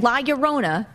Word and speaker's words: La Llorona: You say it like an American. La Llorona.